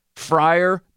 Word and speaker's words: Freiermuth: One of them said during the broadcast that Freiermuth told them during the week that that's how you pronounce his name, Freiermuth.